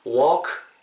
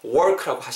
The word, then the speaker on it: walk: walk, W-A-L-K인데 여러분 발음하실 때 work라고 하시면 안 됩니다. L 사운드는 무음입니다.